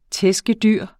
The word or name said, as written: tæskedyr